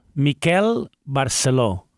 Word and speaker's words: Miquel Barceló: Miquel Barceló. – All right. – With an accent on the O.